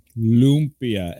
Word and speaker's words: lumpia: Lumpia. Lumpia. L-U-M-P-I-A.